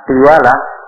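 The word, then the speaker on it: tiwalah (تولة): Nah, Kemudian yang ketiga watiwalah jika cerita tiwalah dengan kasroh wafat wafat fathil wawnya fathah tiwalah taknya kasroh ti wawnya fathah wa bacanya tiwalah tiwalah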